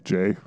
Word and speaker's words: Jai: This is the film with Bruce Campbell, Michael Jai White. Is it Jai? Is that how you say it? – It's like Jay.